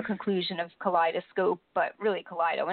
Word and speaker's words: Kaleido: And you know, to be honest, Christine, I was just dying because when I was reading your bio, at first I'm like, how do you say this name? Is it Kaleido? Kaleido? And then it was so great because at the end you had your, you know, the quote of Kaleidoscope and what it means. And I was like, oh, okay, there you go. Perfect. I got the pronunciation correct.